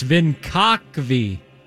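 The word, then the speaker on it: Vinkovci: That makes sense because the oldest inhabited city in Europe is the eastern Croatian city of Vinvaki. I pronounced that right. – Vinvaki. I think you nailed it. Vinvaki? – No, actually, I'm sorry. I did that wrong. It's Vinkakvi. Vinkakvi.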